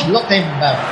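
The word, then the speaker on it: Schlotterbeck: ¿Cómo es que lo pronuncia usted? Schlottenberg, ¿no? – Schlottenberg.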